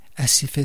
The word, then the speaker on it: Asifeh: Boycott, ça s'écrit B-O-I-K-U-T-T. Asife, c'est A-S-I-F-E-H.